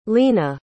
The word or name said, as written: leena